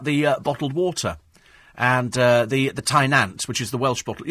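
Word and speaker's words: Tŷ Nant: But apparently, Thai is pronounced the same as tea. Tynant.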